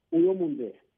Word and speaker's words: Uyomunde: Yeah, when you say it in Lozi, when you want to tell somebody you are beautiful, you say, Uyomunde.